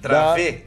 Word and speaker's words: Dravet: síndrome de Dravet, ou Dravet, eu não sei pronunciar. – Dravé, Dravé. – Dravé, é francês, da, da filha Clarian.